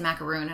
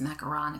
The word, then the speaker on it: macaron: I always said macaroon, and I was just informed that it's macaron if it's the French version.